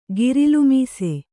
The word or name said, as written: ಗಿರಲುಮೀಸೆ